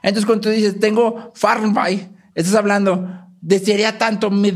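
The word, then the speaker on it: Fernweh: En realidad se pronuncia Farnby. Es una palabra alemana y se pronuncia Farnby.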